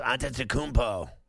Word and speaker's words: Antetokounmpo: Yes, Antetokounmpo.